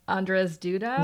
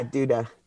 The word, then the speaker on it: Andrzej Duda: So, you know, Donald Trump in the United States, the president of Poland, whose name, how do you pronounce his name? Andrzej Duda? – Yeah, Duda. Yeah, you got it.